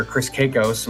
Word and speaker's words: Chris Kakos: But my favorite match happened to be the last match for the Stunny Cup, and it was against my opponent here, Chris Kakos.